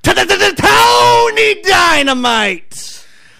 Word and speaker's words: Tony Dynamite: We got to say it right. I was blessed with this name. I was told how to say it every time. It's Tony Dynamite.